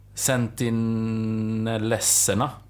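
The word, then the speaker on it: Sentinelleserna: Eh, och där bor en befolkning som heter, ska se om jag kan uttala det Sentinellesserna. – Mm. Det är säkert rätt, jag har ingen aning. Ja, det är rätt.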